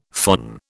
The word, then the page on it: phon: British English